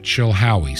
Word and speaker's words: Chilhowie: Post Office Box 510 and we are in Chilhowie, C H I L H O W I E, Chilhowie, Virginia.